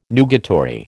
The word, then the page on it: nugatory: American English